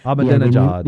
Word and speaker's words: Ahmadinejad: Ahmadinejad. – The Iranian president. – I was just messing with you. – Ahmad. Chris does it really good, but I don't have it down quite yet. – Ahmadinejad.